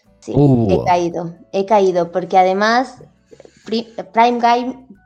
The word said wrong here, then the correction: Prime Gaming: espérate que a ver si me sale el nombre, Prime Gaming, ahora lo he dicho bien,